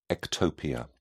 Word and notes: ectopia: British English

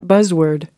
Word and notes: buzzword: American English

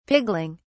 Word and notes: pigling: British English

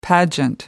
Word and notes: pageant: American English

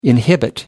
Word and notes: inhibit: American English

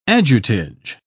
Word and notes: ajutage: British English

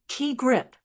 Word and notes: key grip: American English

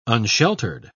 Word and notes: unsheltered: British English

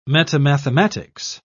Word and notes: metamathematics: British English

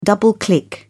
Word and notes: double click: British English

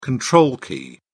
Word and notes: control key: British English

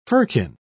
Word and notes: firkin: British English

